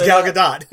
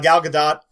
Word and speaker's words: Gal Gadot: Gal Gadot, which I found out from watching Jimmy Kimmel. That is how you pronounce her name. It is Gal Gadot. – How do you say that? – Gal Gadot plays Wonder Woman.